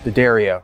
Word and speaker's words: D'Addario: And I say it right, D'Addario.